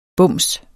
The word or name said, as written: BUMSENS